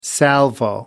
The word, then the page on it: salvo: American English